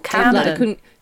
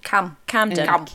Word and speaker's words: Camden: Oh, Camden. Because you all say it was such a short A. – Camden. – Yeah. I had to think of it as CM and go Camden. Camden.